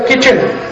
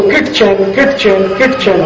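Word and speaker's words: kitchen: अंग्रेजी का एक शब्द है किचन, स्पेलिंग क्या है KITCHE है, तो किट चैन होना चाहिए। वो कहते नहीं नहीं टी साइलेंट है तो क्यों घुसा है, हटा दो उसको बाहर, है मूर्खता कि नहीं।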